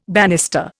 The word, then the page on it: banister: Indian